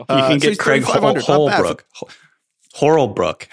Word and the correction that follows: Horlbeck: How do you say your last name? Horlbrook. – Horlbeck. – Horlbeck. – God dang.